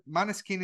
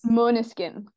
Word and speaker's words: Måneskin: Am I correct that Maneskin is a Danish word? – It is. It's actually pronounced Måneskin.